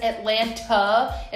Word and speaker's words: Atlanta: And anybody, by the way, who fucking says Atlanta is not from Atlanta. Because we don't even pronounce the T's, That's some bullshit.